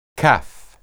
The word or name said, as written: caph